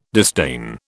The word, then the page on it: disdain: British English